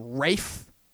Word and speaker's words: Ralph: Like, you know, like the actor Ralph Fiennes, you know, the guy who plays Voldemort in the Harry Potter movies, you know, they pronounce his name Rafe. I'm like, dude, your name is not spelled Rafe. It's pronounced Ralph. I don't care if you're Welsh or whatever. Your name is spelled Ralph.